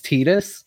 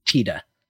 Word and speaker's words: Tidus: I'm just going to tell you, it's Tidus. – Yeah. – You can still call him Tidus. Nobody will arrest you. But the Japanese pronunciation is based on the word for sun, which is Tida.